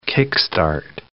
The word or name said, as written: kick-start